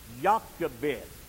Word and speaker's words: Jochebed: Our text would indicate that it was his mother, Jochebed. That's how that's pronounced. The first part of that is from Jehovah, Yah, Jochebed.